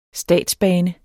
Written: statsbane

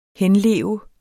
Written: henleve

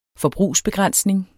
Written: forbrugsbegrænsning

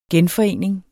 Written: genforening

